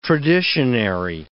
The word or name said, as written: traditionary